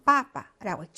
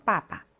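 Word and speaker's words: 빠빠 (папа): 자, 그래서 아빠는 빠빠라고 했죠. 역시 강세가 있으니까 앞에 빠가 훨씬 더 길게 발음되겠죠. 빠빠.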